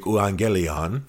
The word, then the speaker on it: euangelion: Uh, in the original Greek of the New Testament, uh, good news is a word that is pronounced in Greek euangelion. Uh, it's a Got him an EU sound at the beginning. Uh, later generations hardened, hardened up that U and made it a V.